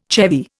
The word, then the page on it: chevy: Indian